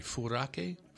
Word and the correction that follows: Furikake: Furikake. – Furikake. There's that extra K.